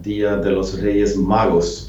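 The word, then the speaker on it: Día de los Reyes Magos: Eh, även mat och andra förnödenheter i denna tuffa period fördelades ut med hjälp av sponsorer och lokala restauranger under 13-dagsafton, Dia de los Reyes Magos, tror jag att den heter på spanska. – Ja, det fick du till ett perfekt uttal tror jag.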